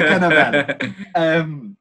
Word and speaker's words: Cannavale: Bobby Cannavale is... – Oh, is that how you pronounce it? I didn't want to correct you, but Bobby Cannavale.